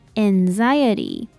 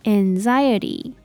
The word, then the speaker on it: anxiety: 音，那最后的 S-I-O-N 念起来比较像是忍的音，那重音放在第二个音节念作 confusion, confusion。接着是 anxiety, 我们先来看前面的 A-N, 在这里它念作 N, N, 后面的 X-I-E 念作 zire, zire, 那最后的 T-Y 为 T,然后重音放在第二个音节 anxiety, anxiety,